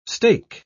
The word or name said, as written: stake